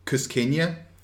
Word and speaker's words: Cusqueña: This is pronounced Cusqueña, and it's Peruvian.